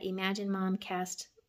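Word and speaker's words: Emagine Momcast: And I said this wrong in my other two videos, so if you watch them, make sure you catch this. It's on Facebook at Imagine Momcast. Facebook at Imagine Momcast. My page is called Imagine Momcast. E-M-A-G-I-N-E. M-O-M-C-A-S-T. I said it wrong before, so I wanted to make sure you guys got that.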